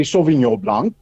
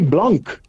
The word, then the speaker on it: Sauvignon Blanc: Ons sê Sauvignon Blanc So daar's al bietjie vir, maar ons sê blank in terwyl ek moet natuurlik eintlik weer sauvignon blanc, né? Nee? Dis eintlik die korrekte uitspraak daarvan.